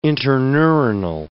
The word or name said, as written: interneuronal